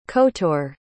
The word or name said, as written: kotor